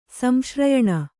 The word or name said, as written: ಸಂಶ್ರಯಣ